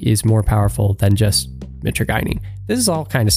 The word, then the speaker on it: mitragynine: So first off, makes sense, but Kratom, taken as, you know, the dried leaf, it's less powerful than taking the primary alkaloid mitragynine by itself. That's another thing I learned. It's pronounced mitragynine, not, as I've been saying this whole time, mitragynine. So there's something. Mitragynine.